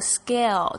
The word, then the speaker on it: scale: Scale，我们要特别注意一下这个 scale，这个 C 的发音呢，通常在 S 后面呢是发 G 的声音。